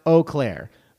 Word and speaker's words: Eau Claire: He says it's pronounced Eau Claire.